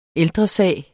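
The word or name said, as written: ÆLDRESAGERNE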